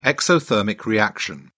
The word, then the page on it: exothermic reaction: British English